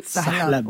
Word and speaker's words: salep: Salep. – Oui, nous on dit salep ici. – Leçon de prononciation de l'arabe.